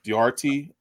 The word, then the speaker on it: Duarte: I can't say it. Never say his name right. Duarte. – Yes.